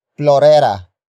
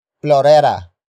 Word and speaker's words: plorera: Word for word it's ihanap mo ng plorera ang bulaklak. Ihanap mo ng plorera ang bulaklak.